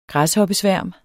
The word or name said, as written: græshoppesværm